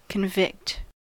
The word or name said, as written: convicted